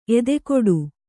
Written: ಎದೆಗೂಡು

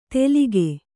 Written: ತೆಲಿಗೆ

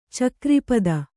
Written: ಚಕ್ರಿಪದ

